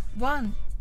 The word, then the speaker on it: 王: 中国人によくえ王様の王と書いて王さんという名字の方いらっしゃいますよね中国語だと「王」は「ワン」と発音するとのことで王さんが「ワンさん」となりますいかがでしょうかそれでは発音の練習をしましょうまずは英語で「ワン